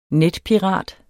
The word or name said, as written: netpirat